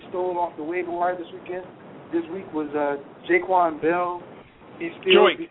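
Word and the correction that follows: Joique: Joik. Get his name right. It's Joik. Joik Bell.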